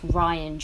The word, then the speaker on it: Rian: Yeah, and I think that's why I like The Last Jedi so much, because I've also just learned that his name is pronounced Rian Johnson and not Rian Johnson.